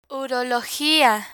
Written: urologia

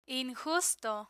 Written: injusto

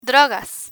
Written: drogas

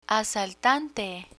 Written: asaltante

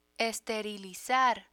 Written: esterilizar

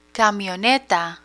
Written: camioneta

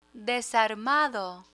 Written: desarmado

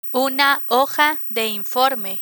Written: una hoja de informe

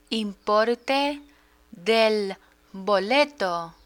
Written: importe del boleto

